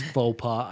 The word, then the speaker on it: faux pas: It's faux pas.